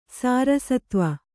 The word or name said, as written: ಸಾರಸತ್ವ